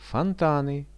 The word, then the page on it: fontany: Russian